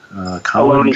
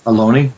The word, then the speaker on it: Ohlone: It mentions you are a lecturer at Ohlone. Am I pronouncing that right? – Ohlone College. – Ohlone, there we go, in Fremont, California.